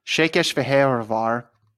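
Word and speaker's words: Székesfehérvár: It sounds like Szechuan, Szechuan. – It is. Shakesh Vahevar.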